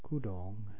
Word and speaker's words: kudong: For example, the double OO in the second part of the word for pants, kudong, makes you naturally accent the second syllable, the correct pronunciation.